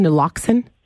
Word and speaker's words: naloxone: Is it called naloxone? – That's correct.